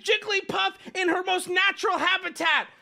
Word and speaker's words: Jigglypuff: Because Jigglypuff is a combination of two words that when combined have three syllables.